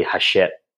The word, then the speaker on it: Hachette: It's really Hachette, apparently. It's a French pronunciation in the UK.